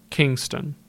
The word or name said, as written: Kingston